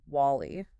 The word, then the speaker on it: WALL-E: The entire planet knows this movie is called WALL-E. – It is spelled W-A-L-L-E.